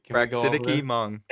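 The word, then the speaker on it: Praxidike Meng: Praxidiki is how they pronounce it. – Praxidiki. What? – That's how they say it. I don't know. – Oh my, that's messed up. – Praxidiki. How do you say it? – Praxidiki. – That's how you say it. – It sounds awful. – Praxidiki Mung. That is the official pronunciation.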